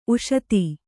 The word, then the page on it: ಉಷತಿ: kannada